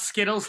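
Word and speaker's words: Zkittlez: It's pronounced Skittles like the candy, but it looks like every time I say it, I always want to say Skittles. – Yeah. – There's a Z at the front.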